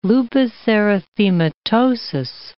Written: lupus erythematosus

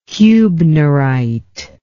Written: huebnerite

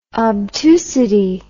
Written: obtusity

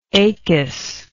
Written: acus

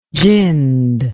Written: ginned

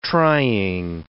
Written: trying

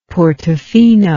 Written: Portofino

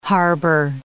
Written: harbour

